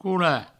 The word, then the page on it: colar: coula